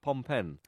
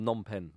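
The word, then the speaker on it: Phnom Penh: No, he wrote the book Up Pompen. – Okay. – Or Nompen, depending on how you pronounce it correctly.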